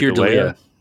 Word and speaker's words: Keir Dullea: Keir, Keir Dullea. – Dullea, yeah.